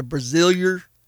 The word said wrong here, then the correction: Brazoria: County. Is that right? How you say that? Brasilia, Brazoria County has more species of birds than any other area in North America.